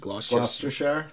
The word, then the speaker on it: Gloucestershire: Gloucestershire.